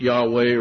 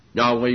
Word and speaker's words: Yahweh: Our Elohim is, you can pronounce it Yahweh or Jehovah, either one, Yahweh, Yahweh, or transliterated, our one God is Jehovah, Jehovah.